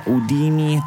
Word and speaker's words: Houdini: Houdini si direbbe in francese, ma la pronuncia anglosassone della cantante superstar Dua Lipa è questa, Houdini, tratto dal live al Tiny Desk Concert pubblicato su YouTube la scorsa settimana e che ha già totalizzato milioni di visualizzazioni.